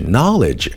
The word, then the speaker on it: knowledge: 我们再回过头来看看 knowledge 知识这个单词，无论是 knowledge 还是 know，字母 K 都是不发音的，所以有一些跟知道相关的同源词干脆就没有字母 K。